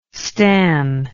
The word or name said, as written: stan